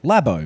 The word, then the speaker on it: Labo: Labo. – Labo. – Like a lab.